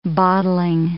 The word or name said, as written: bottling